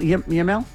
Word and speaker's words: Yamal: Lamine Yamal.